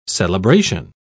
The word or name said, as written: celebration